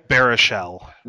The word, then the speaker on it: Baruchel: Baruchel. – Baruchel.